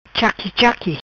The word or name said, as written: Chaqui Chaqui